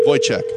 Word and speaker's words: Wojciech: Wojciech.